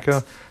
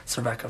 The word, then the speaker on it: Cervenka: Was Exene, I can never pronounce her last name, from X, the band Cervenka. – Cervenka, for sure.